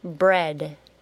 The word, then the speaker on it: bread: Bread. B-R-E-A-D. Bread. Compare the sound in the word bread to the key words. Did you say it is the short E sound?